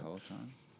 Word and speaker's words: Peloton: It's not something that happens on a Peloton. Is that how you pronounce it? – Peloton.